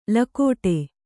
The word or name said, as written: ಲಗುಡಿ